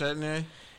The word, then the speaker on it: Etienne: The big news this week has, or the past week and a half, really, Clemson running back, how do you say his name? – Travis Etienne, ETN.